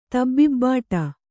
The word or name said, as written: ತಬ್ಬಿಬ್ಬಾಟ